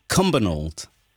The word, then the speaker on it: Cumbernauld: Did I pronounce that right? – Yep. – Cumbernauld.